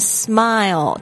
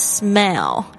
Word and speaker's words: smile: Wake up and smile. 大家特别注意一下，它的发音不是 smell.